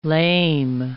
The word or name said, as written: lame